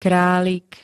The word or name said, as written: kralik